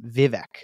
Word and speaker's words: Vivek: But his name is Vivek. That's how you say his name, Vivek.